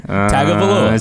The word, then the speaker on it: Tagovailoa: There's no N in there. I did not put an N in there. Tagovailoa.